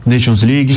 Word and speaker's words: Nations League: Nation League, né? – Nations League.